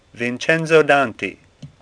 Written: Danti

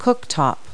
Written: cooktop

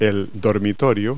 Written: bedroom

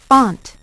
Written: font

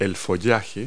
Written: foliage

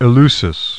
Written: Eleusis

